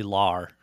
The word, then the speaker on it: Lahr: Did I pronounce that right? – Lar. It's Billy Lahr.